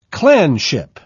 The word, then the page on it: clanship: British English